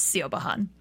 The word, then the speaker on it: Siobhan: It's not spelled like C-H-E-V-O-N-N-E. Yeah. It's S-I-O-B-A-H-N. So it's Siobhan.